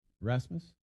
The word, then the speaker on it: Rasmus: Rasmus? – Yeah.